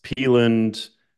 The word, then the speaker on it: Pelland: Usually it's Pelland, Pelland, or people will just think that the N is an R, so they'll still like Pellard.